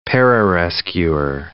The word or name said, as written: pararescuer